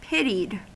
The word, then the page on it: pitied: American English